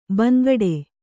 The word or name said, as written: ಬಾಂಗಡಿ